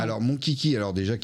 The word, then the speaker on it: Monchichi: Alors, c'est pas Kiki, Kai. C'est mon Kiki, ça s'écrit M-O-N-C-H-I-C-H-I.